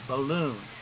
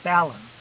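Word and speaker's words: balun: First of all, I've never heard it pronounced that way, balloon. Everybody I've heard calls it a ballon, accent on the first syllable.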